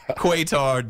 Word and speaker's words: Qatar: It is spelled Q-A-T-A-R, but it is not Qatar. It is not Qatar, dog. It is like a cut fastball. It's Qatar. Qatar is the host city.